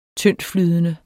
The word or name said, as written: tyndtflydende